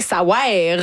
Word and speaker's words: Ça wère: Ça veut rien, ça wère. Vraiment, ça s'écrit de même, il faut le prononcer comme ça.